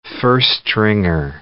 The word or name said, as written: first-stringer